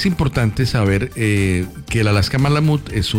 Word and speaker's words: Malamute: Dice, buenos días Bésame, buenos días doctor, tenemos una Alaska Malamute. – Malamut. – Tenemos una Alaska Malamut de 7 meses.